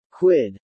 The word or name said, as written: Quid